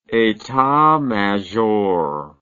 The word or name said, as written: etat major